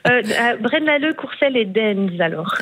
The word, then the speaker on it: Deinze: Et il y en a un, je m'excuse pour la prononciation, c'est Dinze. Ça s'écrit D-E-I-N-Z-E. – Ah, Dinze.